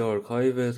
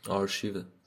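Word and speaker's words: archive: تلفظ انگلیسی آرکایو تلفظ فارسی که از یکی از زبونه اروپایی اومده قطعا آرشیوه بنابراین جفتش درسته تو فارسی حتی بهتر که آرشیو بگید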